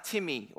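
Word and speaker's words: timi (τιμή): Yet for honor, it is pronounced timi, or spelt time with a little apostrophe, timi. To respect as precious, to give weight, to give significance, high value.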